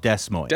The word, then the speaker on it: Des Moines: Just clarification, Des Moines is spelled Des Moines.